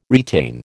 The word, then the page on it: retain: American English